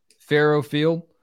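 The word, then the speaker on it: Faurot Field: Farrow Field.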